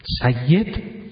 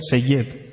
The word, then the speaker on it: سید: مشهدی ها میگن سید، غلط میگن این رو در عرف جامعه، اما شما میگید سید، شما قشنگ میگید با همون عادتی که دارین که فتح رو زیاد به کار میبرید اینو درست تلفظ میکنید.